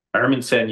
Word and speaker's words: Hermansen: Hermansen, you got it.